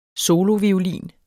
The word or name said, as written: soloviolin